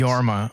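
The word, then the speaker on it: Jorma: It's Yorma.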